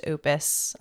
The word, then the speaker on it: Opus: It's stylized as Tact Op Destiny, but it's pronounced Opus, and Opus being like a body of work in music.